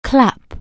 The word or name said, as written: clap